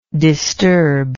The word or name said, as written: disturber